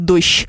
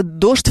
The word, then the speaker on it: дождь: Дождь или дождь, туман Ёшка. Изначально, конечно, нормы произношения предполагали, что он дождь. Но поскольку все меняется, то сейчас уже дождь вполне себе допустимо. Хотя, конечно, академическое произношение будет дождь. Но при этом не надо говорить дожди, потому что во множественном числе они дожди.